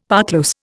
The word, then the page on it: parclose: Indian